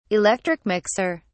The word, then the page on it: electric mixer: British English